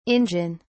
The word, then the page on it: injun: British English